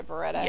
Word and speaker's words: Beretta: But now he's saying stuff about shooting him, her with like a Beretta. Is that right? Did I say that right? A Beretta? – Yeah.